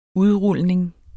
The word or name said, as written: udrulning